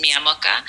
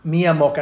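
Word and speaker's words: Miamoca: Is how I pronounce my name. My name is Maria Veronica, and I couldn't pronounce it when I was little. So it's Miyamoka.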